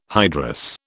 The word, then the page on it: hydrous: British English